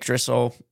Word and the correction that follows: Driscoll: Okay, yeah, well, I know, it's just, you always say it wrong, so I had to say it. – Driscoll.